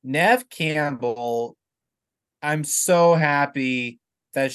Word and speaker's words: Neve: I think it's pronounced Neve Campbell. – Oh, is it actually Neve Campbell? I mean, yeah, Neve Campbell. I believe it's Neve. That's how I say it.